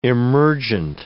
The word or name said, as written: emergent